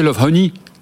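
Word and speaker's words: Lovehoney: Je crois que vous prononcez Love Honey. – Love Honey, oui. C'est vrai.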